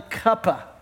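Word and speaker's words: quppah: The first thing was called the cuppa, is the way it's pronounced.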